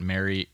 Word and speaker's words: Mario: Number six, I feel like I'm most excited about Mario Hezonja, and I don't know if I'm saying that right. But if he plays in Philadelphia, you're saying his first name right.